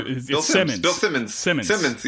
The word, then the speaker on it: Simmons: Am I saying it wrong? Bill Sims? Simons? Whatever. – Bill Simmons.